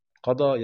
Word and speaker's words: قضى: لا هقول لك ان كلمه ضحى وندى وقضى تكتب ياء، يعني تنتهي بالياء وتنطق الف، يسموها الالف اللينه او الف القصر او الف المقصوره، لأن أصل الفعل بيكون بالياء، يقضي قضى يقضي، فتنتهي بالياء لأن أصل الفعل في المضارع وتنطق بالألف.